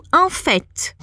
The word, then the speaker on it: en fait: É o en fait. Quando a gente fala en fait, a gente pronuncia esse T no final, que normalmente não é pronunciado.